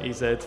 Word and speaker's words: EZ: So we're doing, it's DJ EZ, which is spelt E and then the Z. EZ in English.